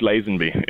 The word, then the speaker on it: Lazenby: He was George Lazenby, if I said that correctly. – You are correct. You are spot on.